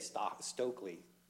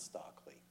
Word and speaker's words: Stokely: I don't mean to interrupt you, Commissioner Wingate, but just for the benefit of Mr. Lowry, they say Stokely here, not Stockley. I just thought it might be worth noting that it is said that way, and you pronounced it how it looks, but it's like Frederica versus Frederica.